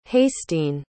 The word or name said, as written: hastiin